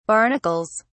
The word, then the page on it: barnacles: British English